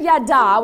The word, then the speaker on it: yada: And write this down, because the word yada, which is the correct Hebrew pronunciation, means to know, to be deeply known and deeply respected.